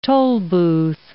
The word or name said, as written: tollbooth